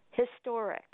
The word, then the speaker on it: historic: But the H is properly pronounced in British English. The Oxford English Dictionary says that the H is properly pronounced, so it's historic.